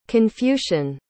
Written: confucian

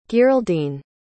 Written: gearldine

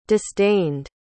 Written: disdained

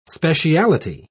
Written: speciality

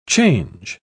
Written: change

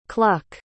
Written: Kluck

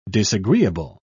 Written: disagreeable

